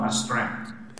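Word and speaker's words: astreinte: Mr. Popovich's French is better than mine, but it's close to Astrain. – Astrain? – Yeah.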